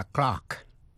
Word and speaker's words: clerk: Thế còn cách phát âm của chữ clerk, miền Nam nước Anh, trong tiếng Anh chuẩn, received pronunciation, chữ clerk đọc là clerk. Trong tiếng Mỹ, đọc là clerk với vần ơ. Tiếng Anh đọc là clerk với vần r. Bây giờ ở Mỹ chỉ dùng chữ clerk, C-L-A-R-K, để chỉ tên người mà thôi.